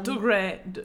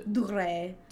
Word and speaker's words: Dougray: Oh, Dougray. – Dougray.